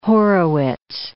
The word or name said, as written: Horowitz